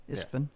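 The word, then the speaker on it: Istvan: Or how does he pronounce his last name? Is it Isfin? – Yeah, Isfin.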